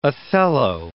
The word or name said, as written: Othello